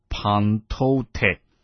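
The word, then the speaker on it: pantote (πάντοτε): 这个常常这个字呢，希腊文读作 pantote。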